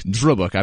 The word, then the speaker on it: Drøbak: Drøbak, I believe, is the proper pronunciation.